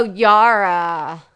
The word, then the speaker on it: Yara: Who's Jara? – What's the name of Theon's sister? – Oh, Yara. – That's a soft J.